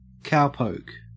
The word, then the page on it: cowpoke: Australian English